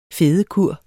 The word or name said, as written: fedekur